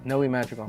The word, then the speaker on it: Noe Madrigal: Yes, Noe Madrigal.